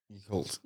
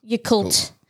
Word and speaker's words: Yakult: I don't know how to pronounce it. Is it Yakult? Yakult? – Yakult. – Yakult.